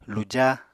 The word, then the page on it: luja: Apatani